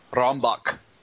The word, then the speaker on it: Rammbock: Yeah, it's spelled like Rammbock. – And it sounds very German. – Yes. If you say it like it's German. Can you say that like you're a German border crossing guard asking me for my papers? – Rambok. – You didn't sound harsh enough.